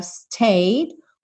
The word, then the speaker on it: stayed: Lucky to have stayed where we have stayed. Stayed. Come you are. Nada de stayed, okay? Stayed. Finge que ele não tá aí.